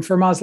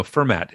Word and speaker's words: Fermat: No, Fermat. It's there's a T on the end. Anyway, if you want to pronounce it, just drop the T. That's kind of a Pittsburgh pronunciation thing, I know, to drop the last letter of stuff. So, but it's Fermat.